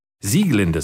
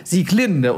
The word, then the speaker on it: Sieglinde: Sieglinde sehe ich hier gerade aus der Walküre, weil nämlich alles bei Wagner auf der ersten Silbe betont wird. Ja, also das wissen viele auch nicht. Nun, die alten Wagnerianer legen da sehr viel Wert drauf. – Ich mache es immer falsch. Ich mache nämlich immer Sieglinde und die Walküre.